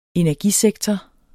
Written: energisektor

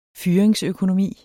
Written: fyringsøkonomi

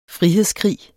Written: frihedskrig

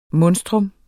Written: MONSTRUMMETS

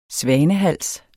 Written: svanehals

